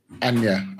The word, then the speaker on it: Anya: Is it Anna Taylor Joy or Anya Taylor Joy? Anya.